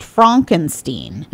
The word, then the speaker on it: Frankenstein: So this wickedly inspired reimagining of Brooks' classic comedy film thrusts Dr. Frederick Frankenstein, pronounced Frankenstein, mm-hmm.